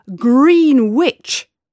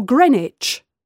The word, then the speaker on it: Greenwich: Then you won't say Slough or Slow for Slough or Green Witch for Greenwich.